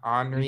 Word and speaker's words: Henri: It looks like it's spelled Henry, but I know that's not how you say it. – It's like Desjardin. Like, Desjardin. Henry Desjardin. Henri.